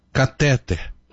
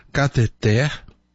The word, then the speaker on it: cateter: Nobel, rúbrica, austero, púdico, gratuito, recorde, ínterim, catéter, mister. Percebeu alguma coisa errada? A pronúncia de todas essas palavras está errada. Há erro de prosódia, e erro de prosódia é chamado de silabada. Como seria então a correta pronúncia, a correta acentuação dessas mesmas palavras? Seria assim: Nobel, rubrica, austero, pudico, gratuito, recorde, interim, cateter, mister.